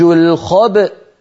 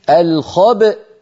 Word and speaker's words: الخبأ: لأن في أصل الكلمة همزة متحركة في الوصل، لما وقفنا عليها سكنت الهمزة فلا بد من نطقها، فنقول الخبأ الخبأ، هنا نخرج الباء المقلقلة بالتباعد بين طرفي عضو النطق بين الشفتين يعني، ونجعل الوترين الصوتيين في الحنجرة ينضمان ويصطدما حتى تخرج الهمزة الساكنة التي هي في الوصل همزة مفتوحة الخبأ.